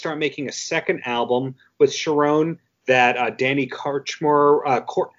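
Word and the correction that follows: Kortchmar: how do you say his name? – Korchmar.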